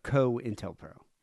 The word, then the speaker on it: COINTELPRO: No, COINTELPRO. – COINTELPRO. See, yeah. Because the co is part of it, it's not co and then like a dash or anything. It's all one word.